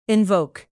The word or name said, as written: invoke